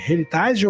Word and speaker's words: heritage: Heritage ou Heritage? Herança em inglês, como pronuncia? Heritage. Me ajudando aqui.